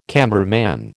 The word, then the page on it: cameraman: American English